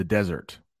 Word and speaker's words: desert: The soldier decided to desert his tasty dessert in the desert. So there's just a little difference on the way we pronounce those words. Dessert, desert. Dessert, desert.